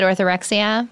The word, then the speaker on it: orthorexia: is that how you pronounce it, orthorexia? – Yeah.